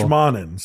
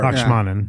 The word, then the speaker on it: Lakshmanan: Lakshaman is his first name. – There's one more syllable. – Lakshmanan. Sorry. – Lakshmanan.